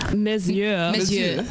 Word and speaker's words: Mes yeux: Mes yeux.